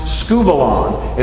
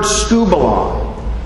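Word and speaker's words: skubalon (σκύβαλον): The Greek word that the ESV renders as rubbish here, eh, according to Strong's Concordance, is pronounced skubalon.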